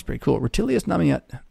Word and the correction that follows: Rutilius Namatianus: I almost had it. Rutilius Namatianus.